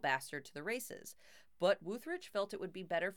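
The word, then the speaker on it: Wütherich: He went off and set about preparing the car for the Salinas sports car races with his Porsche mechanic, Rolf Wutherich. Pretty sure that's how you pronounce it. It's W-U with an umlaut. T-H-E-R-I-C-H. So I'm pretty sure it's Wutherich.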